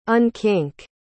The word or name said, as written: unkink